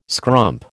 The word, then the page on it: scrump: American English